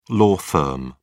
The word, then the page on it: law firm: British English